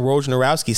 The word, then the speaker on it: Wojnarowski: ESPN's Adrian Wojnarowski